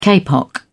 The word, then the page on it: kapok: British English